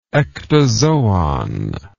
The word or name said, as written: ectozoon